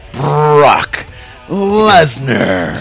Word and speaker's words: Lesnar: Brock Lesnar.